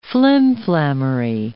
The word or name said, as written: flimflammery